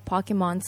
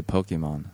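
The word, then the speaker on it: Pokémon: It's, you know, whether you go into a new cave or whether you just decide to stay in the bush and then keep beating up all the yowai Pokemon so that you get... – Pokemon? – So you can get, like, 経験値. – Yeah. – Or, you know, you can go talk to people とか。なんか決まってるじゃん。 – Americans say Pokemon. – Pokemon. – And you Pokemon. – Said you said Pokemon, which is like a weird it's like bimyoni in the middle.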